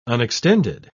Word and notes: unextended: British English

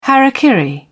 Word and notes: hara kiri: British English

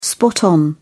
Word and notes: spot on: British English

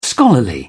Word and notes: scholarly: British English

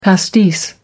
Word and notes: pastis: American English

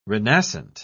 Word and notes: renascent: British English

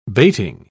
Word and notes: bating: British English

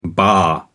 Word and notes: baa: American English

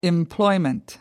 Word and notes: employment: American English

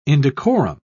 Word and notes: indecorum: British English

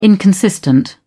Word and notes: inconsistent: British English

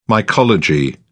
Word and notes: mycology: British English

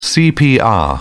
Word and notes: cpr: British English

